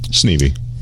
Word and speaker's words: Sneeve: Did I say your name right? – Sneevy. – Sneevy. Okay. So the E is not silent.